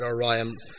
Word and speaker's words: ORIAM: And it's complete coincidence that ORIAM rhymes with Ryan.